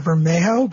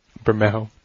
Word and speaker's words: Bermejo: I pronounce it Bermejo, but I'm I don't know. – No, that sounds right. That seems like the Spanish way to say it. Bermejo.